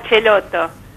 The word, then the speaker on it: Celotó: Espero haber pronunciado bien el apellido, y si no... – Estano, lo pronunciamos como Celotó.